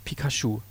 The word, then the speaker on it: Pikachu: Meisterdetektiv Pokémon, Pikachu. Ja, Meisterdetektiv Pikachu. Da waren wir. – Wird das so französisch ausgesprochen? – Nee, das heißt eigentlich Pikachu.